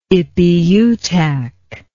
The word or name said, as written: Ipiutak